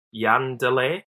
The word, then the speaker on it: Jan Delay: So the guy, correct me if I'm pronouncing this wrong, but there's a German rapper called Jan Delay.